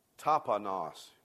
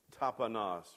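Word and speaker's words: taponos (ταπεινός): This word that's used there for humble is this word taponos. Will say that with me? Taponos. Do we have it up there? I can't see from here. Okay. Taponos. It's pronounced, that's the way it's pronounced.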